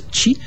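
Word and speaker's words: chi (四): Parce que le chiffre 4, qui se prononce chi, est un homophone du mot pour la mort, qui se prononce chi aussi.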